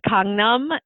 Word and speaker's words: Gangnam: How do you actually pronounce it? – It's Gangnam, yeah.